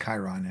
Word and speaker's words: Chiron: It's pronounced Chiron, actually. – Chiron, sorry.